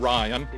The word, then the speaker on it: Rian Johnson: So no, it's, it is Ryan. He, it's he's, Ryan.